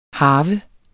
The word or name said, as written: harve